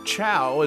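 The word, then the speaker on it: Chao: And then I realized, I'm like, no, you're saying Chow, as I've been made to understand it, but, you know, cow.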